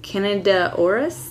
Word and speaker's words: Candida auris: Candida auris? – Very good pronunciation. Candida. Candida auris.